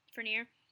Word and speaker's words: Fenrir: Fenrir? – Oh, yeah. Fenrir.